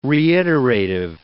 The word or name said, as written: reiterative